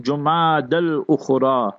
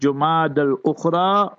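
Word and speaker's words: Jumada al-Ukhra: So you can pronounce it Jumad al-Ukhra or Jumad al-Akhira. And Jumad al-Akhira is better according to some ulama. But both are permissible. So whether you say Jumad al-Ukhra or Jumad al-Akhira, that's the proper pronunciation.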